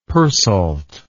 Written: persalt

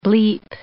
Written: bleep

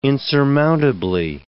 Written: insurmountably

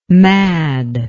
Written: madd

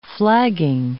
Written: flagging

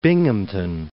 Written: Binghamton

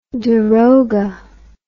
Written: darogha

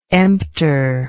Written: emptor

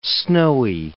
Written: snowy

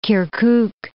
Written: Kirkuk

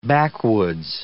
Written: backwoods

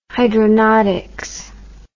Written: hydronautics

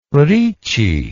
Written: ricci

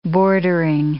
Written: bordering

